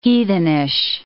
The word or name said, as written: heathenish